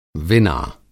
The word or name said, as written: विना